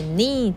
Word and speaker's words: knee: 注意 K 不发音所以 knee 就是 K 然后 N-E-E knee If you promise not to throw me out of the window again 公主就说 I promise 我保证 Princess Poppy will you marry me?